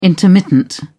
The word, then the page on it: intermittent: British English